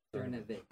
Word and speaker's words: Cernovich: Cernovich.